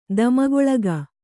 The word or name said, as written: ದಮಗೊಳಗ